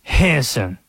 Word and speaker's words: Henderson: Do the people in Henderson, North Carolina, pronounce it that way? They do not. It is two syllables the way that people from Henderson pronounce it. Henson.